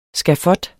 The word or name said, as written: skafot